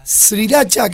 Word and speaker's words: sriracha: que serían unos 120 mililitros de sriracha, que se pronuncia así,